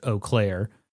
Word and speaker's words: Eau Claire: I was also thinking about how I had no idea the name of that town was pronounced Eau Claire. Whenever I would see it, I would be like, oh, it's... – Ew, Claire. – Eau Claire. Yeah. I had no idea how to say it. – Disgusting. – Or like Eau Claire.